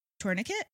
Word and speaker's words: tourniquet: The nurse there, Izzy, we're going to be just introduced to her, is able to create a tourniquet, right? I said that right? – Yep.